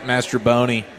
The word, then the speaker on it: Mastrobuoni: Is it Mastrobuoni? Is that how you say his name? Yeah, Mastrobuoni.